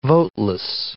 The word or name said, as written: voteless